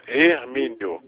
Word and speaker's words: Hermínio: Não, não. Hermínio, com H.